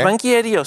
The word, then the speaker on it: banqueiro: Agora vamos analisar porque é que o Ricardo Araújo Pereira se declara banquiério. É esta a pronúncia? Eh, sim, é, É? banqueiro, sim.